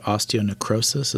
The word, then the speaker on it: osteonecrosis: You have a degenerative disease called osteonecrosis.